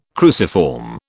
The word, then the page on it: cruciform: British English